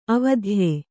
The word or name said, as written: ಅವಿದ್ಯೆ